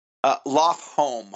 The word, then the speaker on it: Lofholm: Loftholm, home like house.